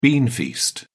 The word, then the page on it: beanfeast: British English